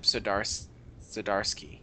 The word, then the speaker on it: Zdarsky: Writer Chip Zdarsky, yeah, I was saying it right,